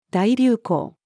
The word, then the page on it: 大流行: female